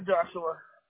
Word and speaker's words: Joshua: Yeah, for Joshua. – And how do you spell it? Is it J-O-S-U-A? – J-O-S-H-U-A.